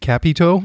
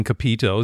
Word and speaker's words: Capito: I thought that Jos Capito, I guess that's what pronounces it, Capito. I think I've been calling Capito, so there you go. Kind of good to hear it from the horse's mouth, so to speak.